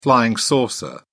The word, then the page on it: flying saucer: British English